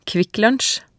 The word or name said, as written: Kvikklunsj